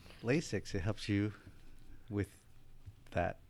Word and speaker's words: LASIK: What is, am I saying it right? Is it LASIKs? – LASIK. – LASIK is what you give.